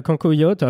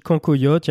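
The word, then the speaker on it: cancoillotte: moi je dis cancoyote, il y a des gens qui disent cancoyote. – Cancoyote. il y, a plusieurs manières de, de, le prononcer. C'est un des produits emblématiques de la gastronomie franc-comtoise.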